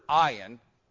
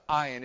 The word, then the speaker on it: ayin: There's no letter in English that corresponds to ayin. It's a silent letter in Hebrew.